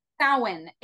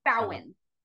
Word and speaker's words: Samhain: So you said that was the Silence Symposium? – Samhain. It's spelled Samhain. It's a Celtic word that means, it's the same time as Halloween. – Okay.